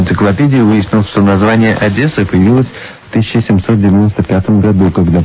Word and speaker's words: Одесса: Что касается произношения города Одесса, то «э» оборотного в нем нет, тут ты прав на все 100%.